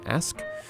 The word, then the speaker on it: Asch: Many know about the ethnomusicology of Willie Lomax, of Moses Asch, I actually never learned how to pronounce that name, Ask.